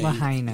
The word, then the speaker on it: Lahaina: Hawaii lawmakers are responding to rising worry over what prevented alarm systems from alerting people in, how do you pronounce this, Lahaina? L-A-H-A-N. – Yeah, I think it's Lahaina.